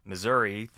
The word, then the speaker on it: Missouri: Back in the 1890s, there was a debate over should we pronounce Missouri, the two S's in there, as Missouri, so it's a sibilant S, like snake.